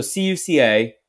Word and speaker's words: Cuca: I am spell it again. So C U C A, Cuca.